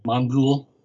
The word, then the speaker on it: Mongul: There's always, where's Mogul? If that's how you say his name. Is it Mogul? Mogul? Mogul. – Mongul. – Mongul.